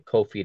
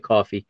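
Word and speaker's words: Kökçü: Coffee, looks like Kofi to me, but it's they're pronouncing it Coffee.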